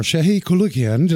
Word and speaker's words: Shahe Koulloukian: And I couldn't do this without, we're honored to have a local rock star, Shahe Koulloukian.